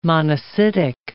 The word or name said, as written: monocytic